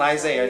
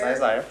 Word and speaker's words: Isaiah: So Isaiah chapter 46, it's not Isaiah, it's Isaiah. Isaiah chapter 46 and verse 10 is where we're going to start from.